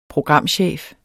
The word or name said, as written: programchef